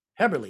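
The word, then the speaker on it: Häberli: It's actually pronounced Haberli. – Haberli, excuse me.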